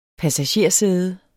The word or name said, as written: passagersæde